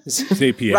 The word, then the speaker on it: Zapier: How do you say it? Zapier? – Zapier. – Now you scrambled Zapier. – Me. – Zapier. – Rhymes with happier. – Zapier.